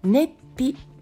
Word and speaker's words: 熱秘: 熱という漢字に便秘の「ピ」と書いて「熱秘」と呼ぶ、えー、便秘の方があります。